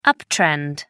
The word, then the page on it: uptrend: American English